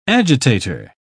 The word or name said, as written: agitator